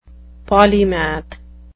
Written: polymath

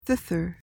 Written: thither

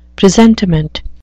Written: presentiment

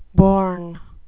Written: bourn

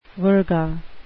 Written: virga

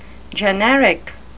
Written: generic

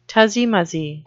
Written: tussie-mussie

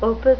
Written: opus

